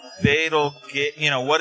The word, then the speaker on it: Betelgeuse: Yeah, so it's because I think that's actually it's actually pronounced like Betelgeuse,